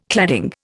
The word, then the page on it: cladding: Indian